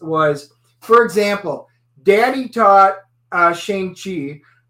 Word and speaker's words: Shang-Chi: It's not Shang. You would piss off the Asians mispronouncing their names. – Yep. – It is Shang-Chi.